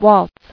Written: Waltzer

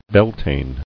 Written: Beltane

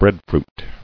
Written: Breadfruit

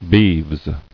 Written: Beeves

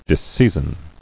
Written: Disseizin

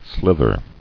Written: Slither

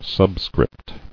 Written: Subscript

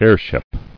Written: Heirship

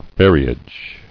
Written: Ferriage

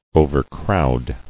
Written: Overcrowd

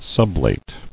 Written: Sublation

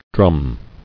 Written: Drumming

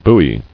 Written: buoy

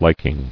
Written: Liking

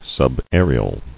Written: Subaerial

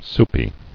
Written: Soupy

